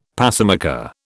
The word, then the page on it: pacemaker: British English